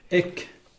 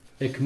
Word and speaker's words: ek: Ek, ça s'écrit E-K, et ce préfixe indique le début d'une action ou sa soudaineté.